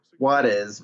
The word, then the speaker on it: Juarez: He actually, it's pronounced Juarez, Mexico, and like it's a Mexican kid, and you're just gonna name it Juarez.